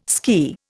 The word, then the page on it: ski: Indian